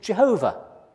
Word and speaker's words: Yahweh: So actually, we don't know how it was pronounced. The Victorians thought it was Jehovah. Nowadays, we tend to say something like Yahweh.